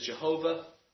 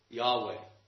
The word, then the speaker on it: Yahweh: In Hebrew, in the English version of the Hebrew letters, whenever you see capital L-O-R-D, you're seeing this Hebrew name. It's the name that God took to himself. This is the same as what's sometimes said as Jehovah. From the best evidence suggests, they didn't have vowels, but the best evidence suggests that this is actually how they pronounced it, Yahweh.